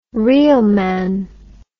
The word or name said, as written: reelman